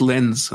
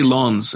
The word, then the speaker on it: Lens: I used to say Lons, and that's wrong, apparently.